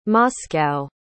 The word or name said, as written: moscow